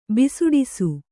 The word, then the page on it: ಬಿಸುಡಿಸು: bisuḍisu